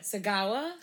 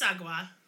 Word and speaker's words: Sagawa: Oh, yeah, it's Issei Sagwa. – Yep. – Sagawa? – It's Sagwa. – Sagwa. – Yeah. I looked up how to pronounce it. So if you guys are mad and we pronounced it wrong, then take it up with whoever made the pronunciation key.